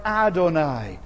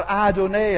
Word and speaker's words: Adonai: It's the word Adonai, or Adonai, as some would pronounce it.